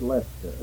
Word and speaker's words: Leicester: It's pronounced Leister.